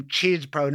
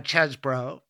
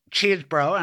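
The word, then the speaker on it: Chesebro: With Cheesebro there, and that's the way he likes to pronounce his name, Cheesebro, not Chesbro, Cheesebro, and I'll give him that courtesy.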